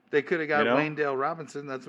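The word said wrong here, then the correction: Wan'Dale: It's not Wayne. It's Wendale. Sorry. W-A-N apostrophe D-A-L-E.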